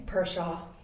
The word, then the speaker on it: Perschau: Perschau.